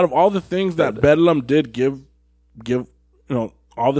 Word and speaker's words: Bedlam: They would pronounce it Bedlam. – Mm-hmm. So instead of Bethlehem, it was Bedlam.